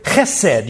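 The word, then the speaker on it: chesed (חסד): It's the word chesed. You got to spit a little bit to say it right.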